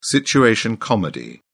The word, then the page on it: situation comedy: British English